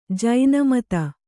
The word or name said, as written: ಜಿನಮತ